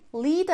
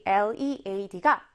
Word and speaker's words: lead: 선생님, lead는 lead 아니에요? 이렇게 물어보는 친구들 있을 것 같은데요. 우리 lead가 lead로 읽힐 때는 동사, 이끌다 이런 뜻이고요. 이렇게 lead로 읽힐 때는 납이라는 뜻으로 알아두시면 되겠습니다. 철자는 똑같은데 발음이 달라지는 특이한 경우죠. 자, 다음 보겠습니다.